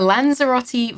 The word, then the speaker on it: Lanzarote: A Lanzarote volcano on a brownie.